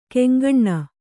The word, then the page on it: ಕೆಂಗಣ್ಣ: keŋgaṇṇa